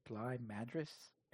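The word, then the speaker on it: Bligh Madris: according to Baseball Reference, at least, Bly Madris. – Okay. – The emphasis is on Driss.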